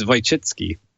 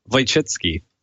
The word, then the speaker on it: Wojcicki: It's Wojcicki. – So I'm so glad you know how to say this. One more time. – Wojcicki.